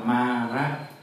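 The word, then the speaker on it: Māra: Tiếng Bali nó gọi là Mara. M-A dấu ngang trên đầu, R-A. Đọc là Mara.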